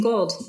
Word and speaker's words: Gould: Yeah, Christine Gould.